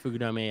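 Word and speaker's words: Fukudome: Fugadome. I think I said that right. – Yeah, Fugadome, yeah.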